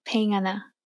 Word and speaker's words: pengarna: Pengarna. – Yeah, that's perfect pronunciation, actually.